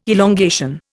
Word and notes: elongation: Indian

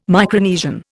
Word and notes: Micronesian: Indian

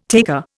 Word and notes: taker: Indian

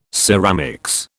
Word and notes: ceramics: British English

